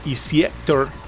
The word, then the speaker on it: EC8OR: Que pues bueno, dicen que se debe de pronunciar, bueno, así es como aparece escrito, pero realmente se debe de pronunciar como Eciator,